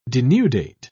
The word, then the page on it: denudate: British English